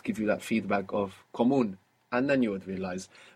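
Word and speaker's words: común: Is it comuno? Comono. – Comun. – Ah. – So there's no way to know that. – Comuno or comun? – Comun. – Comun. – Comun with the accent on the end.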